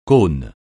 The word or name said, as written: cône